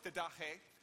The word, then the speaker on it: didache: Now, I know it's didache because I actually went and said, I don't know how to pronounce that. And so in my study time, I went and found the little thing and clicked on the little icon that said it for me.